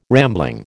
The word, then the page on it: rambling: American English